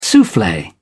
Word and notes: souffle: British English

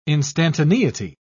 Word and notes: instantaneity: British English

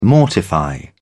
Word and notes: mortifying: British English

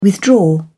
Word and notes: withdraw: British English

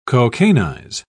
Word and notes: cocainize: British English

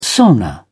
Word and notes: sauna: British English

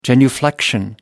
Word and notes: genuflection: American English

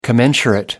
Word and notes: commensurate: American English

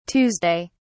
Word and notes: tuesday: British English

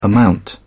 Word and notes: amount: British English

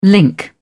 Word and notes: link: British English

